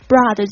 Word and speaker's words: broad: OK， broad wholesome charitable views， broad 是指这种很宽广的，注意一下它的发音，这个 O A 是发 R 的这个声音，就是那个在音标里面呢，它是这个。